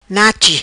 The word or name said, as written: Nachi